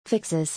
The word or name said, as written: Fixes